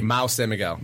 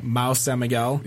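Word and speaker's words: Mahou San Miguel: Mao San Miguel. – Mao San Miguel.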